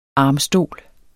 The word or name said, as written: armstol